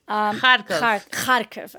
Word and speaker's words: Kharkiv: Kharkiv, okay.